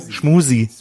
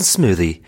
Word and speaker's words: Smoothie: Ich finde, ihr solltet erstmal einfach Smoothie richtig aussprechen. – Das wird mich schon freuen. Schmusi. It's a smoothie. It is a smoothie. – Now repeat after me. Smoothie. Smoothie. Smoothie.